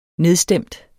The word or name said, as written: nedstemt